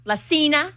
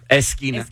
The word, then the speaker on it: esquina: La cena. – Esquina.